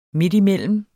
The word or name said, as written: midtimellem